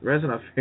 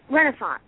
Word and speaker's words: Renaissance: Those fairs, resonant fairs. – Renaissance.